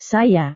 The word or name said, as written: saya